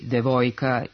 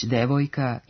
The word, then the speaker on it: devojka: U srpskom standardnom književnom jeziku postoji pravilo da u unutrašnjim slogovima višesložnih reči ne mogu stajati silazni akcenti. Stoga nećemo reći devojka i vojnik, kako se kaže u nekim našim dijalektima, već devojka i vojnik.